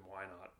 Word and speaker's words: Whynot: Well, I mean, for the past 25 years, it's just been Whynot, like exactly like the question.